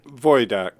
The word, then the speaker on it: Wojdak: And your last name, Paul, I'm pronouncing it as Vidak. Does that sound correct? – Wojdak. – Yeah, Wojdak. – Yeah, Wojdak. – That would be the proper Polish pronunciation.